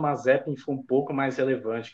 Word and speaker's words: Mazepin: Ou Mazepin, não entendo, não sei a pronúncia ainda. – Mazepin. – É certo.